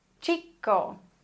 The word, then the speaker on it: chico: CH wird wie ein deutsches TSCH ausgesprochen. Tsch. Tsch. Beispiele dafür wären El Chico, der Junge, Chico. Oder Chino, Chinesisch, Chino. Okay?